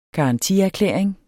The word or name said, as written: garantierklæring